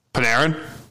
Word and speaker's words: Panarin: I'm going to butcher his last name, but Panarin struggled against New Jersey the entire series. – Panarin? – Yes, there we go.